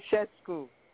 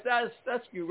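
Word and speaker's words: Ceaușescu: Right, I can never get his name. Virginia's like, you cannot say his name right. – Sessescu. – Right, Sessescu, right.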